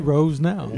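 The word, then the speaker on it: Rosenow: Rosenau. And C. Rosenau? – Yeah. Yeah, C. Yeah. Rosenau.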